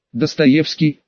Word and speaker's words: Достоевский: Достоевский, автоматически поправил оператор, предоставьте ваш идентификатор корабля, отправил.